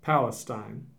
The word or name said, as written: Palestine